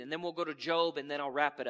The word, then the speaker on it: Job: For those of you that aren't familiar, it says Job in your Bible. But it's Job is how it's pronounced.